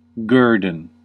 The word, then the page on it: премия: /ˈprʲemɪjə/